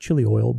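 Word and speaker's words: chilioi (χίλιοι): The word there is chilioi, if I'm pronouncing it right, chilioi, almost like chili oil, but chilioi.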